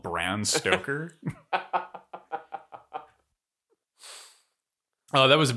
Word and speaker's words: Bram: So, even less known fact, Bram is the incorrect way to pronounce it. It's actually Bran, like the cereal.